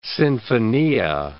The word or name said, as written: sinfonia